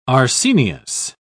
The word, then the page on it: arsenious: British English